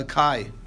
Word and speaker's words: chi: Okay, like a X, like a Greek a chi. That's how you pronounce it, right? Like the Greek letter chi.